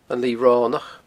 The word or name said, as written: Lighe Ronach